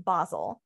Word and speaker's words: Basel: So he ended up next in, we're going to pronounce it Basel, because that's how you would say it in Germany.